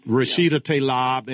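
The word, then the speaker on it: Rashida Tlaib: Rashida Talib.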